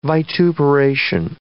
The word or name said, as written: vituperation